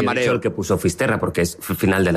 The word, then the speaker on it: Fisterra: Entonces llegó a Finisterre, ¿se dice así? – Fisterra.